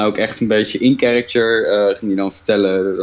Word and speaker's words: character: Nee, niet karakter, gewoon character. Character. Ja. – CH spreek je uit als een K.